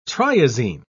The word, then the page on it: triazine: British English